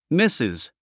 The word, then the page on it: Mrs.: mísiz; ミスィズ